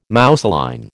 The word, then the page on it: mousseline: American English